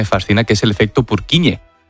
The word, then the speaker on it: Purkinje: En español sería Purkinje. – Purkinje. – Pero Purkinje es en checo, que es porque es el apellido del descubridor.